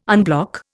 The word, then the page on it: unblock: Indian